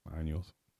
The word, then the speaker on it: años: Baños is bathroom? – Mm-hmm. And años is years. – Oh, años. – Años, not your butthole.